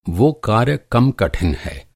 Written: कार्य

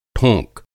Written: ठोंक

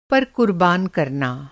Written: कुरबान

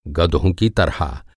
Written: गधा

